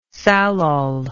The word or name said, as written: salol